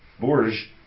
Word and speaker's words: Bourges: 2 days, you're in Bourges. Did I say it right? – Bourges. – Bourges, okay. I'll get it. It's been the April 11th, 2010, Watt from Pedro show, live from Bourges.